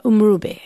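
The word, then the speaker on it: umrhubhe: You play the uhadi and the umrube. – Yes. – Is that how you say it? – Umrube. Uhadi, umrube.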